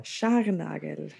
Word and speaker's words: Scharnagl: Scharnagl.